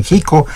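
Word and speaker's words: Xico: También hablando de lo que viene por delante el próximo miércoles en la Plaza de Toros Alberto Valderas en Chico, allá en Veracruz, en Jico, ya también nos hacemos bolas con la pronunciación de la X, en Jico,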